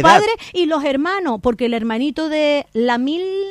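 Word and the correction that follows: Lamine Yamal: ¿lo dije bien, Andrea? Lamin Yamal, el hermanito, vamos, se ha convertido en un icono para la selección española.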